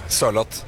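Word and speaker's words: Sørloth: What about Sorlot? How are you supposed to say? Sorlot? How do you say that? – A bit slower. Sorlot. – Sorlot.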